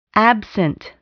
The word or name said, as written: absent